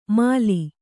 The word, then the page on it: ಮಾಲಿ: māli